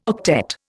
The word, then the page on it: octet: Indian